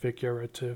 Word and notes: figurative: American English

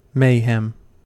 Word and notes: mayhem: American English

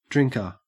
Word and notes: drinker: Australian English

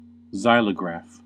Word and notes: xylograph: American English

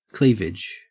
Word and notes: cleavage: Australian English

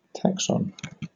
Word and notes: taxon: British English

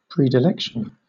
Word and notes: predilection: British English

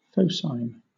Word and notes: phocine: British English

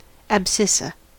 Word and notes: abscissa: American English

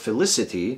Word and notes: felicity: American English

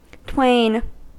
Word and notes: twain: American English

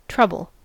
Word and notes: trouble: American English

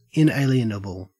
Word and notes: inalienable: Australian English

